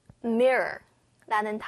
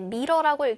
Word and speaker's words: mirror: 자, 그 다음은 mirror라는 단어인데 가끔 뭐 한국어로 미러 이렇게 되어 있는데 미러라고 읽지 않고 mirror 이렇게 읽어요.